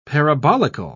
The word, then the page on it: parabolical: British English